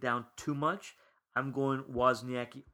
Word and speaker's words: Wozniacki: Wozniacki.